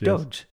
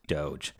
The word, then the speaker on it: Doge: If they're wrong, that's what you call it if you're wrong. It's Doge. Yes, Doge. Yes. – Yeah. – I don't think it's like GIF versus JIF. I think it just literally is pronounced Doge.